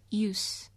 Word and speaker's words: Seus: S-E-U-S. The S is silent. I declare it to be in this poem. Use.